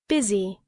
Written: busy

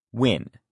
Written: win

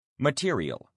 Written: material

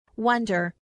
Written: wonder

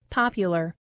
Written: popular

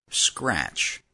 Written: scratch

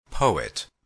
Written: poet

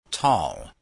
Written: tall

